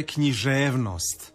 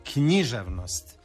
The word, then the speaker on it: književnost: Što se tiče književnosti i književnosti, ja također preferiram naglasak koji se i vama čini pravilnim, a to je književnost. Dakle, na E je dugouzlazni naglasak i na ost je dužina. Međutim, dopušten je i naglasak književnost,